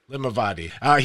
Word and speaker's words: Limavady: Limavady.